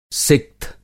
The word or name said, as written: सिक्थ